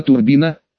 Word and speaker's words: Турбина: Поверь, Турбину ты ни с кем не спутаешь, заверил меня Сергей, но ее и зовут так, Нина Турбина, с ударением на первый слог, разумеется.